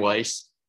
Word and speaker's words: Weiss: I'm Brent Weiss.